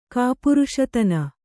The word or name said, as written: ಕಾಪುರುಷತನ